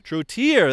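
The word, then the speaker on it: Trottier: We've had David Trotter? – Can you remember Trotier, that's how you say his name.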